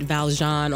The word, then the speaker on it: Valjean: Okay, I was like, oh God, I have said Valjean on the podcast, so I will. – That's okay. That's the really correct pronunciation.